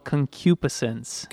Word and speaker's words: concupiscence: It's the word called concupiscence. – Concupiscence. – Concupiscence. – Maybe we should all say it at once. – All right, one, two, three. – Concupiscence.